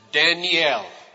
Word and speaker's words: Daniel: The name Daniel has 3 syllables. You pronounce it Daniel. And each one of those syllables in Hebrew has a meaning. Dan means judge. The little E sound in the middle is the Hebrew word for my. And El, the Hebrew word for God.